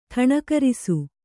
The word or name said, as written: ಠಣಕರಿಸು